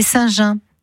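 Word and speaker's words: Saint-Gens: C'était Saint-Jean. Alors, ça s'écrit G-E-N-S, hein, pour ceux qui ne connaissent pas.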